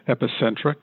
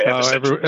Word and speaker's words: EpicentRx: So just to help you out, the company is EpicentRx.